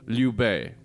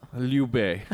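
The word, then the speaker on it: Lyubey (Любэ): Lyubey. – Lyubey. – L-Y-U-B-E. – That's not how you spell that. – Lyubey. Well, the L is very light because it's followed by a soft vowel.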